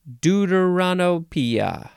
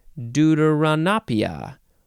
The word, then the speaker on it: deuteranopia: As in deuteranopia. How do you say this word? Deuteranopia. Where is it? Here it is. Deuteranopia. Deuteranopia. That's how you pronounce that word.